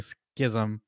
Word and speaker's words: schism: I just used Google's pronouncing, and it's definitely schism.